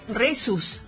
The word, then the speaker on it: Rhesus: El factor RESUS, se escribe RHESUS, y los grupos sanguíneos.